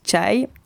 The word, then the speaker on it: chai: Pakora, beignet de légumes, chai se prononce chai. Oui, parce que ça s'écrit C-H-A-I.